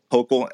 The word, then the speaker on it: Hochul: What do you think is going to happen in the event and consumption industry going forward as you guys wait for how do you pronounce her name? Is it Hochul? – Hochul.